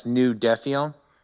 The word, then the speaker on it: Nous Defions: So you're the founder of Nous Defions. Is that correct? Did I say it right? – Yeah, pretty much. It's Nous Defions.